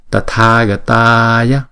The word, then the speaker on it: Tathagataya: Tathagataya Tathagataya. Thấy không? Hai chữ A màu đỏ thì chúng ta kéo dài hai nhịp. Ba chữ A màu đen chúng ta đọc một nhịp mà thôi.